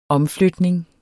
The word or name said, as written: omflytning